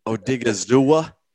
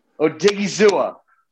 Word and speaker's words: Odighizuwa: Odighizuwa. – Odighizuwa. – Oso Digizua.